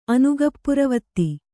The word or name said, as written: ಅನುಗಪ್ಪುರವತ್ತಿ